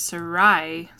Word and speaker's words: Sarai: Yeah, if you read it, it says that Sarai? Is that how you pronounce that? – Yeah.